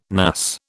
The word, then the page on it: NAS: British English